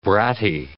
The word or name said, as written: bratty